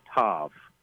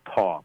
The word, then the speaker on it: tav: It's the letter tav, or some will pronounce it taw, but I prefer tav, which is the last letter of the Hebrew alphabet.